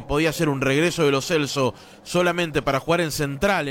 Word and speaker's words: Lo Celso: le dijimos toda la vida Gio Lo Celso y resulta que es Lo Celso, en la etapa, en la cita de pronunciaciones que realizó la FIFA, ¿no, tanque? – Sí, sí, tal cual, va a costar, pero es Lo Celso, de aquí en más.